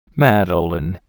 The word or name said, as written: madeleine